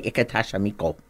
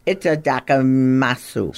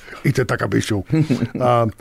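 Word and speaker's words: Itadakamasu (いただきます): And don't say Itadakamito. – Itadakamito. – Did I say that right? Itadakamasu. – Sure, now you say it right. – I heard it enough. – Itadakamasu.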